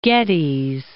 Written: Geddes

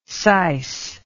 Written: sice

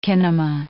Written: kinema

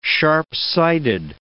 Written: sharp-sighted

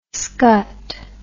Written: skutt

